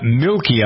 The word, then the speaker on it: Milke: Her name is Deborah Milkey, I believe. That's how you pronounce her name. M-I-L-K-E.